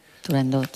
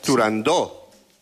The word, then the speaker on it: Turandot: Turandot. – Turandó. – Turandot. – No. – Turandó. – No. No se debe pronunciar Turandot. – Ah, no, no. Turandó. – La ópera se titula Turandó.